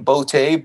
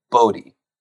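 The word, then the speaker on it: Boutte: And then Kayshon, I think we're calling him Bote, but I actually prepared on this. I heard him, I think he pronounces it Bodie.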